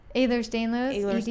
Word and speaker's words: Ehlers-Danlos: Ehlers-Danlos, EDS.